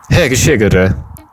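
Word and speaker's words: egészségedre: En dat het accent altijd op de eerste lettergreep ligt van het woord. Dus. Nu jullie weer. Heggeschiggeren. Dat is uh, proost.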